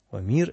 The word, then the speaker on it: Омир: Омир – это Гомер по-славянски, да, потому что он и пишется по-гречески «хомерос», с «о» начинается, но с густым придыханием. Отсюда вот это вот «г» в современном произношении имени. А по-славянски его называли «Омир».